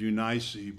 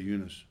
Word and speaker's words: Eunice: Actual pronunciation is Eunice, but it doesn't sound right, so I say Eunice.